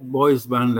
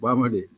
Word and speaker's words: bois bandé: And you know, Barbadians, you know, they would pronounce bois bandé as boys, boys band, and not not bois bandé.